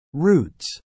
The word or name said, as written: routes